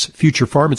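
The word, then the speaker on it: Future Pharm: That's Future Farm. It's spelled P-H-A-R-M, kind of a play on words.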